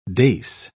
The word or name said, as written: dace